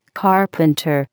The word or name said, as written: carpenter